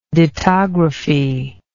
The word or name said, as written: dittographic